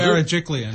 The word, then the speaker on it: Berejiklian: Berejiklian.